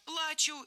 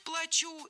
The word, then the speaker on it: плачу: Ударим «а» – «плачу» и хочется плакать. Ударим «у» – «плачу» и придется заплатить.